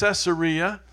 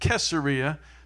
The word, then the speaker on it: Caesarea: He's been extradited from, most people say it's Caesarea. I've been told the locals use a hard C and they pronounce it Caesarea, so I might alternate back and forth.